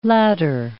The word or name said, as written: ladder